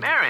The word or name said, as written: Marin